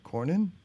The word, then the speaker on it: Cornyn: Cornyn.